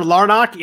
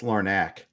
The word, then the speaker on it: Larnach: The first, I'm going to butcher his name probably, Trevor Larnach in Minnesota got called up. Did I say it right or no? – Close. I had to look it up for the XM show today. It's Larnach.